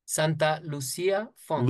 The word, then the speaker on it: Santa Lucia Funk: And that is in, please correct me if I'm saying this incorrectly, Santa Lucia Funk? – Santa Lucia Funk. – Lucia. – That's it.